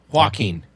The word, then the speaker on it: Joaquin: Joaquin.